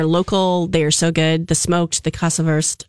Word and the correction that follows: Käse: I hope I'm saying that right. – Yeah, Käse. Because it has an umlaut.